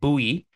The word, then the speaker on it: Boey: So last week, we talked about the signing of Sacha Boey, which is apparently pronounced Bowie, according to the commentators on Bundesliga's live commentary, which I did not expect.